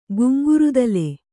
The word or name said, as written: ಗುಂಗುರುದಲೆ